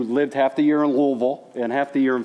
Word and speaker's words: Louisville: Notice how I said that I swallowed my tongue. That's how the locals say it, Louisville, Kentucky.